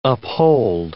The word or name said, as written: uphold